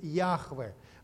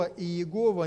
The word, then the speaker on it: Яхве: Там стоят четыре буквы, которые более правильно произносить будет «Яхве». Мы не знаем точно, как произносятся эти слова, но слово «Иегова» – не совсем правильное произношение, это уже исковерканное. Если быть ближе к оригиналу, то это слово «Яхве».